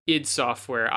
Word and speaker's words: id Software: So id Software, some psychopaths pronounce it ID Software.